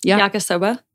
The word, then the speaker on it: yakisoba: Yakisoba. – Yakisoba.